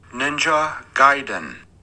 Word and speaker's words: Gaiden: Ninja Gaiden.